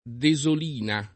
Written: Desolina

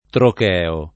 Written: trocheo

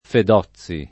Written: Fedozzi

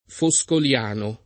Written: foscoliano

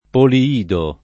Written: Poliido

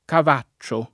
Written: Cavaccio

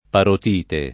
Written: parotite

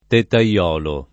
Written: tettaiolo

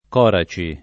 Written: Coraci

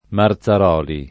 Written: Marzaroli

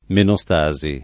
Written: menostasi

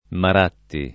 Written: Maratti

